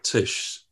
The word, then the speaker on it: Tisch: Was that at Tisch? Is it, am I pronouncing that right, Tisch? – Mm-hmm. Yes.